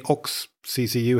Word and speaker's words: OXCCU: Do you say OXCCU? Do you say OX? CCU, how do you pronounce them? – We say OXCCU, and that reflects its history of being spun out of Oxford University.